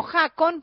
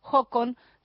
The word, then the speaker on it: Håkon: Håkon se escribe con una A, esa A que tiene un circulito arriba. En realidad leeríamos sí, como Håkon, pero se dice Håkon Skogstad.